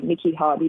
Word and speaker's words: Niki Hardy: So my Niki is spelled N-I-K-I, and then it's H-A-R-D-Y, Niki Hardy.